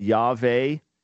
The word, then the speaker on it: Yahweh: In other words, if we put it in English, as we would most rightly pronounce it, Yahweh or Yahweh. What's the difference? There is no difference. Just to explain this, the Hebrew consonant W, when it would come into English, would come into English as a V.